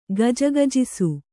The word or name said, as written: ಗಿಜಿಗಿಜಿಸು